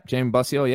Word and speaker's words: Jamie Bosio: You did an interview earlier on with Jamie Bosio. Am I saying that right? – Yeah, Jamie Bosio, yeah.